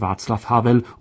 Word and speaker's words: Václav Havel: Václav Havel heißt demnach einfach nur Václav Havel und nicht Václav oder gar Václav, wie sogar Journalisten und Politiker oft meinen.